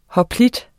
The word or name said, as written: hoplit